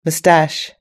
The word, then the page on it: moustache: British English